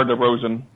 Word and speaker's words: DeRozan: DeRozan. – I don't know. Roseanne. – It's very American. It's just DeMar DeRozan. That's exactly how you pronounce it.